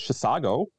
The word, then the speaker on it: Chisago: Two Rivers plays Chisago. Notice how I said that right? – Yes, you did.